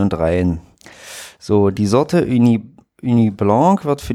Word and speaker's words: Ugni: Aus der Region Gaillac und die Sorte Uni Blanc. Oh, also UGNI geschrieben, ich will es jetzt hier nicht komplett falsch, wie spricht man das? Wenn das UG? – UGNI? – Ja, Uni.